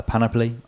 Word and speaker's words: panoply: So war gear wise, he has the wildfire panoply. I can never say that right. Panoply. – I think that's right.